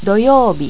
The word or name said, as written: doyoubi